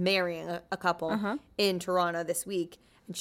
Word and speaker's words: Toronto: I'm from Toronto. – Wait, also, do you pronounce the second T in Toronto? – I do now because, I mean, Americans do. – In Toronto, we say Toronto.